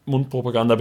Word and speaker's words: Mundpropaganda: Mundpropaganda betreiben. – Betonung auf Propaganda.